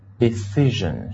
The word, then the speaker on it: decision: 好了，那么回过头来，我想请大家再跟我读一个单词，就是 decide 这个单词的那个名词形式，就是很多同学读的不对哈， S-I-O-N 到底是怎么读的啊，注意一下，就是 decision，是吧？